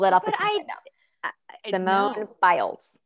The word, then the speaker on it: Biles: Simone Biles.